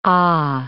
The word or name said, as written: aah